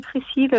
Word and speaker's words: Priscille: Bien en, en on dit Priscilla à l'anglaise, mais c'est Priscille.